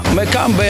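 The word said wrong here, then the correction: Mbappé: jak on? – Mbeppe.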